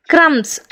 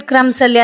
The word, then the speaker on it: crumbs: ಕ್ರಮ್ಸ್, ಹೆಚ್ಚಾಗಿ ಬಳಸಿರ್ತೀರ bread ಕ್ರಮ್ಸಲ್ಲಿ, ಅದು ಏನೋ ಅಡುಗೆ ಮಾಡಿದೆ ಅಂತ. ಕ್ರಮ್ಸ್ ಬರೀ CRUMBS ಅದು, ಆದರೆ ನಾವು ಹೇಳೋದು ಕ್ರಮ್ಸ್ ಅಂತ, ಬಿ ಉಚ್ಚಾರ ಮಾಡೋದೇ ಇಲ್ಲ.